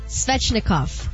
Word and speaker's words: Svechnikov: That's right. Andre Svechnikov. Did I say that right? – Yes, you did. – Right! – You got it right.